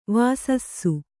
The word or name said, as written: ವೆಸಸು